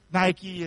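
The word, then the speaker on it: Nike (Νίκη): Nike in Greek is Nike.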